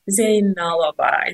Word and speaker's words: Zeynalova: It's Zeynalova.